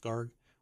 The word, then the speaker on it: Garg: Yeah, Vishal Garg, I guess his last name is pronounced Garg, received a $25 million cash bonus in 2020 after previously requesting an equity-based award.